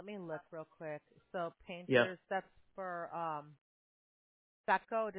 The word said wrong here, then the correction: Saco: We do service Saco, but it's a little bit south.